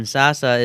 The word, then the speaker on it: Sasse: I'm going to say I do know, I think he may be a descendant of the Lutheran theologian, Harriman Sasse. Is Is it your state senator or representative, Ben Sasse, Ben Sasse, or? – It's pronounced Sass.